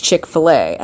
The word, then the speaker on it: Chick-fil-A: Another thing going on in the South, there's this restaurant called Chick-fil-A. And it's spelled C-H-I-K-F-I-L-A. But it's Chick-fil-A.